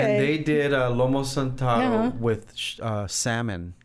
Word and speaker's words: Lomo saltado: Salamataro? How do you say it? – Lomo saltado. – Lomo saltado.